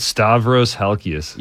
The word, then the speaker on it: Stavros Halkias: Hey, look, how do you say that guy's name? Stravi? – Stavros Halkias.